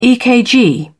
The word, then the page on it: ekg: British English